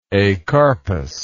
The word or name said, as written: acarpous